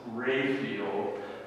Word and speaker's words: Raphael: And I said, "No, it's spelled like that, but in my family it's pronounced Raphael, which is the proper way to pronounce it."